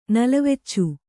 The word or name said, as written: ನಲವೆಚ್ಚು